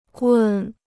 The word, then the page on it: 棍: gùn